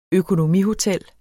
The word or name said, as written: økonomihotel